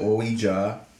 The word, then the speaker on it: Ouija: An authentic Ouija board straight out of the DR.